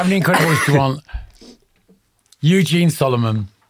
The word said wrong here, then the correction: Souleiman: Souleiman, Solomon, Souleiman. Do you ever come up? – Souleiman.